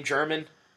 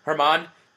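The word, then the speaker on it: Germán: Dude, did you read, Fangraphs had an article about Domingo, do you pronounce his name German? – Herman.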